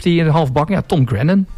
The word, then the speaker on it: Grennan: Grennan, zeg ik dat goed? Grennan, ja, Tom Grennan.